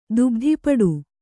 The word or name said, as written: ದುಬ್ಧಿಪಡು